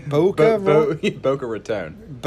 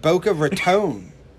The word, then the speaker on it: Boca Raton: Boca Raton. – Boca Raton.